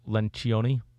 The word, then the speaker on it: Lencioni: Um, the Ideal Team Player by Patrick Lencioni. I think that's how you pronounce his last name. – I'll, I'll agree with you on that.